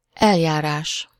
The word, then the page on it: procédure: France; /pʁɔ.se.dyʁ/